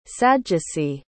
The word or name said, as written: sadducee